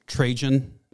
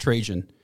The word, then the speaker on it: Trajan: This is when the Roman Emperor, it's pronounced Trajan. Was he trying to make it sound like transgen or... I've always heard it pronounced Trajan.